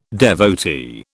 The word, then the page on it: devotee: British English